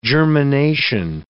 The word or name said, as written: germination